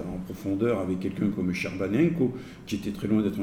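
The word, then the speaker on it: Scerbanenco: Je vous recommande vraiment, c'est écrit S-C-E, on prononce en italien Sherbanenko, c'est-à-dire S-Serbanenko, il faut les prononcer à la française.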